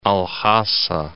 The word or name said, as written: Al-Hasa